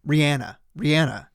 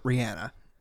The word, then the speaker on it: Rihanna: Rihanna. I saw the other day, she pronounces it Rihanna. Everybody corrects everybody and says, it's Rihanna, but it's actually Rihanna.